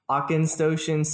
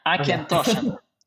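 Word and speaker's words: Auchentoshan: but Akinstoshen Single Malt Whiskey? – Almost right. Akinstoshen. Akinstoshen.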